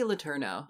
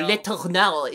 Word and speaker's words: Letourneau: how do you pronounce her last name? – Mary Kay Letourneau. – Mary Kay Letourneau. – Or Letourneau, if you want to be super French with it.